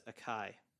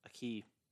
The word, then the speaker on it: Aki: And then Regan Murdoch as Akai. Aki.